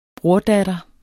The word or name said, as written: brordatter